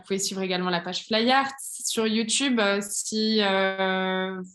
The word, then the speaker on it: Flyheart: Flyart, ça s'écrit F-L-Y-H-E-A-R-T, comme le cœur en anglais.